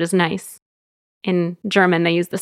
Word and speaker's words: Nice: So the city in the south of France, how do you say that? – Nice. – Okay, just making sure that I didn't have that one wrong too.